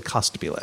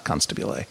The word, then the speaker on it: Costabile: It's David Costabile. I apologize, not Constabile.